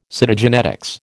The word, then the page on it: Cytogenetics: American English